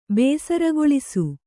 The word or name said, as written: ಬೇಸರಗೊಳಿಸು